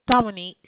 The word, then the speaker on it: Dominique: Dominique. – Dominique. Spell it for me. – D-O-M-I-N-I-Q-U-E. – Q-U-E.